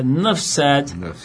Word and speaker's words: Nuff Said: Se escreve, olha, se escreve assim, N-U-F-F, não é Enough, é Nuff Said, de 68.